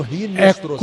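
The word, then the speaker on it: Hinestroza: A pronúncia com R, né? Se escreve com H. Mas a pronúncia em espanhol é com R. Marino Rinestroça.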